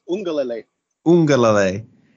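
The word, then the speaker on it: Uiagalelei: Ugiangale. No, I butchered it. – It's Ungalele.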